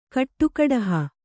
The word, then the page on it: ಕಟ್ಟಕಡುಹು: kaṭṭakaḍuhu